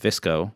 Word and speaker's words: VSCO: Next up, also I think somewhat related, a photo editing app by the name of Visco, which is spelled V-S-C-O, and I think pronounced Visco, shot its way up to the top of the App Store as well, right after Paste Keyboard.